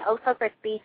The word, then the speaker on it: O'so Krispie: So if you go to Ustream, just type in osocrispy, and people stop misspelling my name. Like, everyone's like, I can't find you because you can't spell my name. So it's OSOKRISPIE, and that's how you spell it.